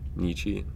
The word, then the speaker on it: Nietzsche: Like, think of Schopenhauer and Nietzsche. I always mess up that pronunciation. – Nietzsche.